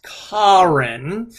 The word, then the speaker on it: Karin: And then the other one is Karen, K-A-R-I-N, and she goes to great lengths to say it's pronounced Karen.